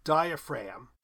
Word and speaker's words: diaphragm: GM endings are silent. Diaphragm. We don't pronounce the G in that either.